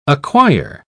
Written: acquire

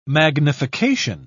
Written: magnification

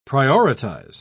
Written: prioritize

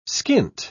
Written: skint

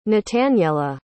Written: nataniela

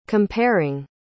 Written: comparing

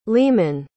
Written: leamon

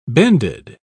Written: bended

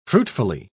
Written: fruitfully

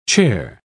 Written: chare

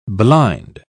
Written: blind

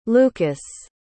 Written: Lukas

